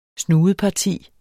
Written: snudeparti

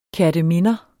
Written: kerteminder

